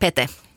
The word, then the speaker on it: Pete: Jag har ju varit lite osäker på hur man uttalar mannens förnamn. För att det har sagts Pete i svensk media, men det är ju då det finska uttalet Pete.